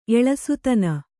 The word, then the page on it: ಎಳಸುತನ: eḷasutana